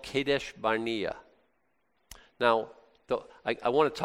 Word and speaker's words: Kadesh: Now, Kadesh means, my mechanic has a tattoo. This is Kadesh. It's a Kof, a Dalet, and a Sheen, and they're It's pronounced, you know, like K-D and S-H, Kadesh.